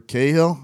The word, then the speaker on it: Cahill: The Angels are throwing Trevor Cahill.